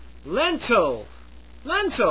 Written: lentil

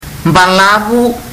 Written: balavu

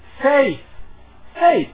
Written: faith

